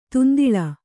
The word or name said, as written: ದುಂದುಲು